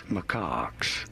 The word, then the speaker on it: macaques: Macaques. Macaques. Pretty straightforward. Once you know, you do want to stress on the second syllable.